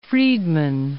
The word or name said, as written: Friedman